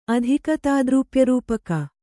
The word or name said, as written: ಅಧಿಕತಾದ್ರೂಪ್ಯರೂಪಕ